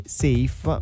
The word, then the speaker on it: Saif: Si è creato anche un caso intorno al nome del rapper genovese, perché c'è chi lo chiama Saif, chi Saif, chi Saif. Ha detto lui che la pronuncia corretta sarebbe Saif, ma fondamentalmente ha detto Wayu, come mi chiamate chiamate, a me va bene sempre.